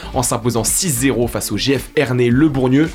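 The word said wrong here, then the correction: Bourgneuf: Je le prononce bien Bourneuf. – Le Bourneuf Bourneuf, ça marche.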